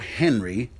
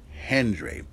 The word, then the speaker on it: Hendry: Not Joe Henry, Joe Hendry. It's spelled H-E-N-D-R-Y.